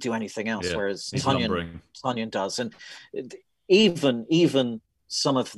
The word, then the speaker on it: Tonyan: And Robert Tonyan rhymes with onion.